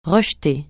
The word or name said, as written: rejeter